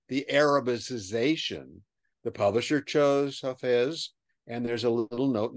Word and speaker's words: Hafez: So we have all these where Hafez is, and I was mispronouncing that earlier, where Hafez.